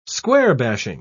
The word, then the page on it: square-bashing: British English